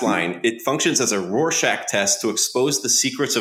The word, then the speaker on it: Rorschach: Is that how you pronounce that? A Rorschach? – Yeah, Rorschach.